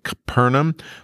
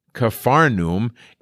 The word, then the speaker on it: Capernaum: And it's kind of spelled a couple different ways in the Greek. A lot of times it's spelled with a pi, so it makes that p sound, Capernaum, but it's also written with a phi, so it's Capharnaum.